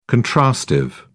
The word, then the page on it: contrastive: British English